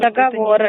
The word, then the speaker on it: договоры: А то, что у вас долги в нашем банке и договора уже направлены в суд, договоры. – Это не интересно вам? – Девушка, договоры. – Договора. – Договоры. Правильно говорить.